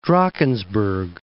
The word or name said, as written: Drakensberg